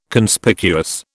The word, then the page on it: Conspicuous: British English